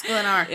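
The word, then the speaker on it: Sklenar: Brandon Sklinner. – Sklinner. – Am I saying that right? – Sklinner. – Sklinar. – Sklinar.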